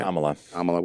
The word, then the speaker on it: Kamala: That Camilla, is that how you say it? – Kamala, whatever.